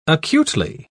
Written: acutely

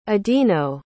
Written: adino

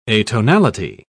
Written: atonality

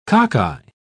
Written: cockeye

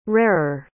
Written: rarer

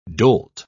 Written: dolt

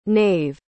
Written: naeve

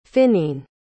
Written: Finneen

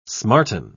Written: smarten

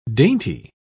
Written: dainty

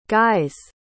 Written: guy's

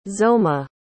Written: zoma

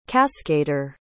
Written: cascader

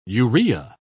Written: urea

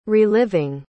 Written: reliving

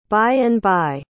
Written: by-and-by

